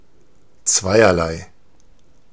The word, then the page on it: zweierlei: /ˈtsvaɪ̯ɐlaɪ̯/